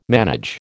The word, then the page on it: manage: American English